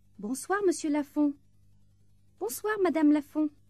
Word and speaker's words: bonsoir: In this section, pay particular attention to the R sound in au revoir and at the end of bonjour and bonsoir.